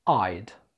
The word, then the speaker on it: I'd: I would، نطقها بقى كده. I'd. كأنها مكتوبة A-Y-D.